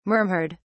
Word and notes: murmured: British English

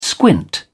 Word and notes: squint: British English

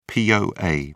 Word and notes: poa: British English

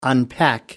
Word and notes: unpack: American English

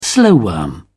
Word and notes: slowworm: British English

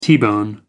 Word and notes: t-bone steak: American English

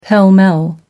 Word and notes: pell mell: American English

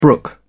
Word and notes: brook: British English

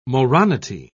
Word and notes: moronity: British English

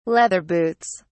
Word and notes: leather boots: British English

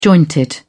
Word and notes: jointed: British English

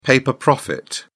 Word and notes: paper profit: British English

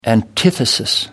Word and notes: antithesis: American English

